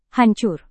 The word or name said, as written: hancur